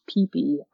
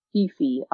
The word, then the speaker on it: Phi Phi: And it's spelled P-H-I-P-H-I, but it's actually pronounced Peepee Island, not Fifi Island.